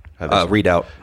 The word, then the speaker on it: redoubt: How do I say this? Redoubt? Redouped? – Readout.